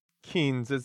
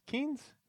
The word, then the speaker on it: Keynes: So, I mean, what's your best understanding of Keynes? Keynes? It's spelled K-E-Y-N-E-S, but he says you can do a stimulus, right?